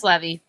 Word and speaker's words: Leve: It's Levy, but everyone says Levy, so I just kind of go with it.